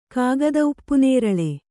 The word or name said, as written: ಕಾಗದಉಪ್ಪುನೇರಳೆ